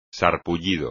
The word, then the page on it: sarpullido: espagnol